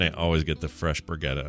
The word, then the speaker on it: Brigitte: Well, She has a name, and we see like a drawing of her in the origin story of Brigitte, which is the way you pronounce it. – Okay, say that again so that everyone can hear that. – Brigitte. It's because she's Swedish, and that is, I mean, I'm sure the Swedes listening to this are going to go like, oh my God, Patrick, stop making a fool of yourself. But that's more or less how you pronounce it.